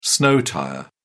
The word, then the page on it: snow tyre: English; British English